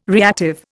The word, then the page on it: reactive: Indian